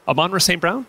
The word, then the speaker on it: Amon-Ra St. Brown: Did you pronounce that right? – Amon-Ra St. Brown? Absolutely.